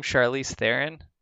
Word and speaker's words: Charlize Theron: Charlize Theron. Did I say her name right? – Yeah, she kind of looks like her in a way.